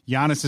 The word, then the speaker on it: Giannis: Giannis is correct.